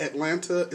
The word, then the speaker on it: Atlanta: I'm an Atlanta native, straight from the southwest side of Atlanta, but Atlanta is always spelled out, and it's not pronounced Atlanta, it's pronounced Atlanta. – It's Atlanta. Yeah. I'm going to pronounce all those letters.